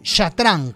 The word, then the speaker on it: chatrang: En Persia supuestamente adquirieron el chatrang y lo llamaron chatrang. Mi amiga Analia Giacchetti me dijo que debería pronunciarlo así, como el chatrang, con ese fondo, ese golpe gutural al final.